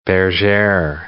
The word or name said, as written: bergere